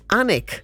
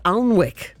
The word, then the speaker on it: Alnwick: So instead of saying Annick, she says Alnwick.